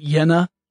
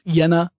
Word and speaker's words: Jena: I suppose it would be Jena, Germany. The J is generally pronounced like a Y.